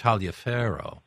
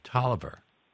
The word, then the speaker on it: Taliaferro: Right, There was a football player whose name would have been pronounced Taliaferro, but he called himself Tolliver.